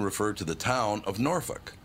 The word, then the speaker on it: Norfolk: It is pronounced Norfolk. That's just how it's pronounced. It's F-O-L-K,